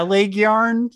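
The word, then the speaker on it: Lægjarn: And I'm going to mess up the pronunciation again. Leg yarn. – Yeah. Leg yarn. Yeah. That's pretty good.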